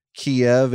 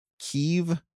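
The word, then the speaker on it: Kyiv: Uh, two readers, David Joaquin Nagel and James, direct us to one of the funnier parts of yesterday's testimony, which was we all learn that maybe we're pronouncing the city of Kiev incorrectly. – yes. – It is not, according to the very straightforward, straight arrows we were hearing from Kiev, it's Kiev. Kiev.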